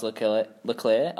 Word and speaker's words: Leclerc: Well, that's Charles Leclerc, I think it is. – Have you pronounced that right? – Leclerc. – It looks like... – Well, no, but that's how he says it.